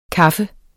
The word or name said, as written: kaffer